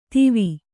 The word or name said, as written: ದೀವಿ